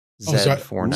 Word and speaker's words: Z490: He's got a review for us of a Z490. Yes, the Z400 series are out, and here's it's pronounced Zed. Z490.